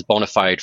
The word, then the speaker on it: bona fide: I've got a, a, financial practice called Bonafide Finance otherwise spelled or pronounced as bona fide finance, but I have a Latin background, and so I like to give it the Latin pronunciation, so bona fide, which is means in good faith.